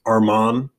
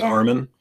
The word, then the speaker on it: Armin: Yeah, changed the spelling of his first name, which is, I think, definitely an interesting Side note about Armin Gilliam, apparently tired of it having been mispronounced Armon when his name is Armin.